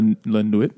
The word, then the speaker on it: Nuit: so Night is uh, the published in 1960 in English, uh, but it was originally published in 1958 in French as La Night, La Nuit. How do you say that word? Nuit. Nuit.